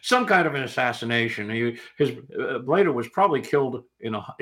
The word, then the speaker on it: Bleda: Well, conversely, and this almost sounded Shakespearean when I read it, Attila the Hun had a brother, and I'm probably going to mispronounce this, Bleda, B-L-E-D. – Yeah, Bleda, yeah.